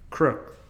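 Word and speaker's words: Kreuk: Crook. Like the guy that robs the cookies from the cereal. – Yeah, okay, good. – He's a crook. – She is literally the same age as Kristen Crook.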